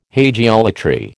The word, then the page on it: hagiolatry: American English